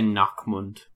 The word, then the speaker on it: Nachmund: Is that how you pronounce it? The Nachmund? – I would say Nachmund. – Nachmund, are we okay? Yep, okay. – The Nachmund, go a little more Gauntlet. Germanic with it.